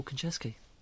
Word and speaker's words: Konchesky: Am I saying that right? Paul? – Yes, you are indeed. It is none other than Paul Konchesky.